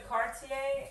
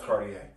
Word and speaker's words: Cartier: We went into Cartier, and they had a choker necklace. – It's pronounced Cartier.